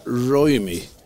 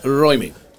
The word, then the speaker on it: Ruimy: Hon heter, jag tror man uttalar hennes namn Karen Ruimi. – Jaha. – Hon stavar R-U-I-M-Y. – Det låter finskt. – Karen, ja, hon är marokkanska. – Ja. – Karen Ruimi, säger jag. Det är väl något engelskt uttal på mitt, förmodligen.